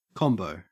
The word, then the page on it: combo: Australian English